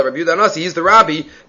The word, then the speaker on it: Rabbi: You see from here that the proper way to pronounce Rav Yehuda Hanasi's nickname is Rabbi and not Rebbe. Because Rabbi is Rabin. That could be confusing. Rebbe and Rabim is not. But says Rav Yaakov Emdin, it's Rabbi.